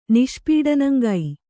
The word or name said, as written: ನಿಷ್ಪೀಡನಂಗೈ